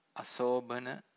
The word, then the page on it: asobhana: Pali